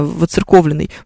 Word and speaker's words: воцерковленный: Кстати, многие говорят воцерковленные с ударением на «ё», но правильно воцерковленный, пишет нам Бор, совершенно верно. А знаете, почему правильно, правильно воцерковленный? Потому что буквы «ё» там нет.